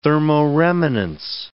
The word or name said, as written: thermoremanence